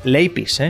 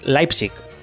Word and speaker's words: Leipzig: Leipzig, ¿eh? como dice José Ramón de la Morena, es Leipzig, Leipzig también pronunciado en castellano rudo.